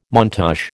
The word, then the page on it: montage: American English